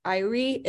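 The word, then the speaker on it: Eyre: I think it's Irie. It's E-Y-R-E.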